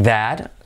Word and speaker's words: that: Number 8, that, like that. ¿Vale? Señalas un objeto, sería that. Cuidado, semiclave TH. Like that. La T última casi no se pronuncia.